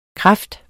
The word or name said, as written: kraft